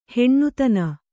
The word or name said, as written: ಹೀನತನ